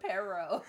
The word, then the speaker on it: Perro: Perro. You gotta roll your R. – Perro. – Say Relo. – Perro Negro. – You can do this. Put your tongue on the top of your mouth.